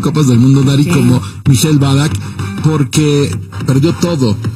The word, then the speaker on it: Michael Ballack: ¿Cómo se dice bien? ¿Michael Ballack? – Michael Ballack, sí.